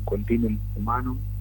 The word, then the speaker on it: continuum humanum: Y yo lo que digo que, bueno, acá hubo un continuo humanum, sería un continuum humanum, se dice así en latín, sí.